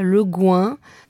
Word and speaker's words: Le Guin: Alors là, les débats sont ouvert, donc vous avez le choix entre Le Guin. Quand vous allez en librairie, souvent on dit du Le Guin. Il paraît qu'elle souhaitait qu'on prononce ça Le Guin. Donc, choose.